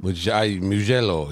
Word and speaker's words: Mugello: Mugello, yeah.